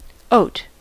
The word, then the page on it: Hafer: /ˈhaːfɐ/